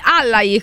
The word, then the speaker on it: Allai: Quindi Allai, questa è la pronuncia corretta.